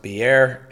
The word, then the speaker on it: Beer: I believe it's pronounced Beer, and it means well in Hebrew.